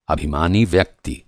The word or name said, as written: अभिमानी